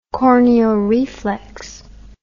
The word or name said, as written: corneal reflex